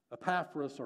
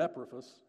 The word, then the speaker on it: Epaphras: Epaphras, or Epaphras, his name is sometimes pronounced,